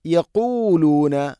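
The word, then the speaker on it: യഖൂലൂന (يقولون): അല്ലദീന അലം തറ ഇലല്ലദീന നാഫഖൂ നാഫഖൂ ഖാഫ് സ്വരഭാരത്തിൽ ഉച്ചരിക്കേണ്ട അക്ഷരമാണ് ഖാഫ്. അതിൻ്റെ മഖ്റജ് തൊണ്ടയുടെ മുകൾ ഭാഗം, അതിൻ്റെയും മേലെ ചെറുനാക്ക്. നാഫഖു യഖൂലൂന യഖൂ യഖൂലൂന അല്ല യഖൂ യഖൂലൂന ലിഇഖ്വാനിഹിം ലിഇഖ്വാനിഹിം ലിഇഖ്വാനിഹിം അല്ല, ഹംസ ഉച്ചരിക്കണം.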